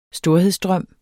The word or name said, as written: storhed